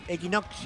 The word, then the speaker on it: equinoccio: No, con doble C. Equinoccio. – Bueno, pero se pronuncia como si tuviera una X, ¿o no? – Equinoccio. – ¿Viste? Como una X. – No, una C y otra C.